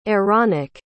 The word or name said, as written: Aaronic